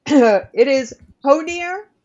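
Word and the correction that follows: Hœnir: The uh, oh. oh nope, it's pronounced Hynir. I wrote that down because I was going to say it wrong which is what I would do.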